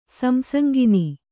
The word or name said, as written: ಸಂಸಂಗಿನಿ